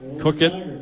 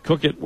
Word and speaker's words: Coequyt: Cook it. His name is spelled C-O-E-Q-U-Y-T. So Cook it.